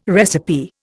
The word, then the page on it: recipe: Indian